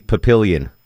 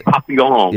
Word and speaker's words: Papillon: Longoria pronounces that movie Papillion. – But Papillon. – Yeah, Papillon.